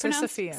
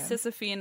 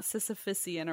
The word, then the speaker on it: Sisyphean: I pronounced Sisyphean wrong. I'm sorry. I'm really upset about it. It's Sisyphean. – Sisyphean, not Sisyphusian or whatever the fuck I was saying.